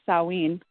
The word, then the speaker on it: Samhain: Samhain is how they – looks like Samhain, but the Gaelic pronunciation is Samhain.